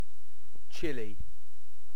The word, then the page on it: chili: British English